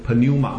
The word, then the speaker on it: pneuma: 那希腊文呢，它也讲到这个灵啊，那这个灵的念法叫 pneuma 啊，那个 pneuma 那个 p 没有发音。